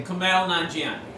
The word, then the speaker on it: Kumail Nanjiani: Kumail Nanjiani.